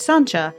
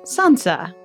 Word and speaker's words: Sancia: a girl named Sancha, although seeing her name spelled out, S-A-N-C-I-A, you might be forgiven for mispronouncing it Sansa.